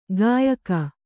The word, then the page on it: ಗಾಯಕ: gāyaka